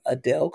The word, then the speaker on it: Adell: And Instagram at I am Adele Coleman. My name is spelled A-D-E-L-L, not E-L-E. I am Adele Coleman on all platforms.